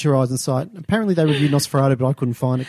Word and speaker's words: NOS4A2: The book is spelled NOS4A2. So the A you pronounce R, so Nosferatu.